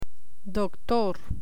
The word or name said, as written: doctor